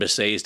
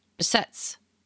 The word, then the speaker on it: Behçet's: This patient ended up having Behçet's disease. – I was saying it wrong this whole time, wasn't I? Behçet's. – Well, yeah, I mean, I have always heard it pronounced Behçet's.